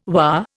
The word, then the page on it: WA: Indian